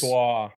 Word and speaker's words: Brossoit: Your next UFA, if we're going to go down the list, Laurent Brossoit. I might have butchered that. I know how to say it. I've heard his name, but he's out in Vegas. – Brossoit.